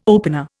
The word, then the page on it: opener: Indian